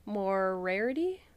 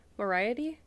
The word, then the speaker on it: Moriarty: I've been trying to figure out Morarity, Moriarty.